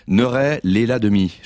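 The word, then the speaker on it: Neurey-lès-la-Demie: Vous êtes né le 31 octobre 1978 à, attention, je prends mon souffle, Neuray-Léla-Demy, je prononce bien ? – Oui, c'est ça.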